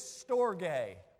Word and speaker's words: storge: The first word is storge.